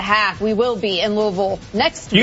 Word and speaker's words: Louisville: You gotta say it right. Louisville. – Louisville. I've been practicing. You can tell I got the peanut butter in my mouth. Louisville. That's how they like it said.